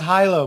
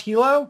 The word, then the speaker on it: Hilo: Oh, it's pronounced Hilo, by the way. – Sorry about that. – No, it's cool. I learned this just a few months ago. I was doing an interview with someone and they called it Hilo. I said, no, it's Hilo. And they said, oh, I know the Hawaiian city. It's like, oh, right. That's pronounced Hilo. It's like, so there's a city in Hawaii named Hilo.